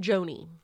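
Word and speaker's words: Gjoni: Iran, I'm going to assume that G is silent. Iran Joni.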